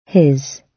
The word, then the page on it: his: British English